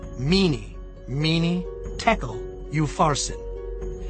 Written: Mene Menen Tekel Uparsin

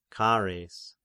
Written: Chares

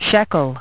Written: shekel

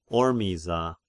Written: Ormiza

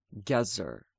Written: Gezer

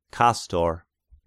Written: Kastor

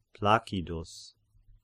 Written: Placidus

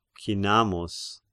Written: Cinnamus

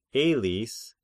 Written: Elis

